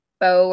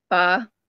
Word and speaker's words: pho: So I love like pho or pho, whichever pronunciation you want to go with, and it's just so good.